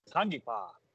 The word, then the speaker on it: Tangipa: Tangipa.